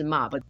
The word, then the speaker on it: Mob: 嗯，这部的剧情设定其实很单纯，他的主角是一个叫做影山茂夫的国二男生，绰号是路人，发音是 Mob。